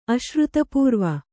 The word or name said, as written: ಅಶ್ರುತಪೂರ್ವ